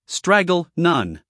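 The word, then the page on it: straggle: uk ˈstræɡ(ə)l; en-us ˈstræɡ(ə)l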